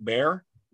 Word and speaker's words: Bexar: They have one called Root Bear. It's spelled B-E-X-A-R, which is a county in Texas.